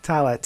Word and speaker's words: Tallah: Uh, is it Tallah? – That's how I've been saying it. Okay. Uh, yep. It's probably fine. Tallah, T A L L A H.